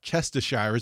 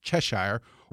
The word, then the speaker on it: Cheshire: And that's how, like, something that ought to be pronounced Chestershire is pronounced Cheshire, or right.